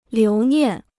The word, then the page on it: 留念: liú niàn